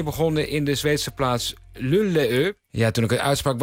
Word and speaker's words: Luleå: Het gaat dus om een plaats die je spelt als L-U-L-E-A met dan een rondje op de A. En zo spreek je dat volgens onze luisteraars echt uit op zijn Zweeds. – Een ringeltje, een kringeltje, een rondje boven een A is een oa. Dus het is Luleå.